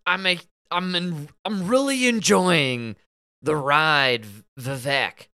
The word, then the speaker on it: Vivek: He's talking with somebody, and somebody brings up Vivek, and Bill Maher goes, "It's Vivek. It rhymes with cake."